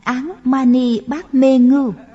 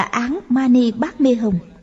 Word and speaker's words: Án Mani Bát Mê Hồng: Khi người ấy niệm, các hạt đậu đều có cảm ứng, các hạt đậu tự nhảy lên. Người ta nói người niệm sai rồi, chú ấy phải đọc là Án Mani Bát Mê Hồng.